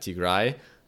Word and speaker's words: Tigray: I probably butchered that. Tigray. I think that's a better pronunciation.